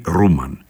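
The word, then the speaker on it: Roman: Ρόμαν γράφεται, τον προφέρουν οι Σουηδοί Ρούμαν, ο οποίος γεννήθηκε στη Στοκχόλμη στις 26 Οκτωβρίου 1694.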